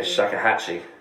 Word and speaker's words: shakuhachi: and you've got shakuhachi, I think, if I'm saying it right. – Yeah, shakuhachi. Yeah.